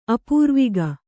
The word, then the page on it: ಅಪೂರ್ವಿಗ: Kannada